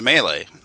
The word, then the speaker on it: melee: And I wish I had read Redbox D&D back in the day more thoroughly, and I wouldn't have gone 30 years pronouncing it melee, because Frank even tells you it's pronounced melee.